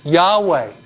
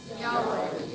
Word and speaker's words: Yahweh: Okay, you pronounce it Yahweh. Say it. – Yahweh. – Say it again.